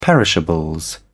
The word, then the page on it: perishables: British English